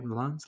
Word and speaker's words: Le Mans: Henry Ford II would build a race car that would beat Ferrari in the infamous 24 hours of Le Mans. Le Mans? Am I saying that right? Le Mans? – That's how we say it in America, baby. Le Mans.